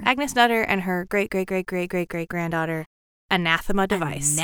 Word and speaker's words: Anathema: Oh, her, it's pronounced anathema, but it's spelled anathema.